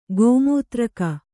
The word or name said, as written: ಗೋಮೂತ್ರಿಕೆ